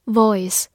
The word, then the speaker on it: voice: Voice, V-O-I-C-E, voice 字母 v 的发音，注意要用牙齿咬住下嘴唇。